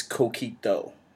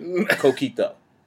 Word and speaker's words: Coquito: It's Coquito. We were saying kind of like Coquito.